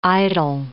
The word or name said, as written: idyl